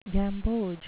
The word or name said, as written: gamboge